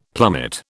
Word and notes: plummet: British English

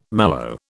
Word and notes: mallow: British English

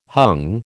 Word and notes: hung: American English

